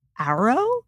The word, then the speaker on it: Auro: I found a company called Arrow. That's how I'm pronouncing it. A-U-R-O Wellness.